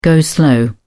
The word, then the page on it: go-slow: British English